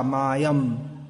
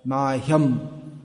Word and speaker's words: Mahyam: That ha has to be not Kada Mayam, Mahyam. Tendency in English is to drop the sound H, but it should be pronounced.